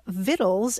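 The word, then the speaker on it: Victuals: There is a book by my dear friend Ronnie Lundy, who lives outside Asheville, and she's written this book called Vittles, An Appalachian Journey with Recipes, and it's spelled Vittulas, Vittles, and it's a love letter to the cuisine and the folkways and the foodways and the history and language of Appalachia.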